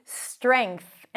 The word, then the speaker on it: strength: And then it's an NG sound, streng, and then TH. Strength, NG sound, and then push the tongue out for the TH.